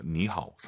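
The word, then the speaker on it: 니하오 (你好): 분명히 천천히 발음하시면 니하오지만 사실 삼성이 마지막 글자면 보통 안 올려요. 니하오. 삼성 삼성이니까 앞에 니는 이성. 니하오.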